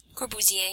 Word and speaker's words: Corbusier: 1952 was Le Corbusier. Is that how you pronounce it? – Corbusier, yeah.